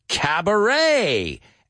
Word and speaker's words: cabaret: Cabaret. And what does it have in common with the words we looked at before? It ends in E-T, but it's pronounced like an A.